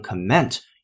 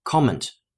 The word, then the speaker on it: comment: 我们在前两天的口语区节目当中讲解这个单词叫 comment，并且还提示它啊注意发音，不能读成 comment，要读成 comment。